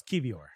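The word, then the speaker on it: Kiwior: How do you pronounce his name? Kivior or Kivior? – No, it's Kivior.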